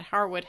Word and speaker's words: Harewood: Harwood House. – Oh, that's cool. – Yeah. The name of the village is spelled Harewood. The house and the title Lord Harwood are pronounced Harwood. Oh, I actually pronounced that correctly.